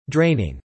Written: draining

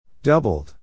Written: doubled

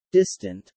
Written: distant

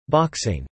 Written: boxing

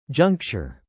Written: juncture

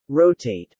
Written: rotate

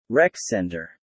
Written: rec centre

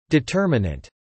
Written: determinant

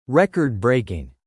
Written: record-breaking

